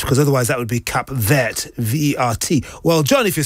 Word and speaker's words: Cap Vert: So the word for green, because Cabo Verde means Cape Green, it would be Cap Vert, V-E-R-T. You don't pronounce the T, though, unless there's an E after it.